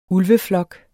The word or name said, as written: ulveflok